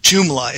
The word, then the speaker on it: Joomla: It's called Joomla. It's spelled J-O-O-M-L-A.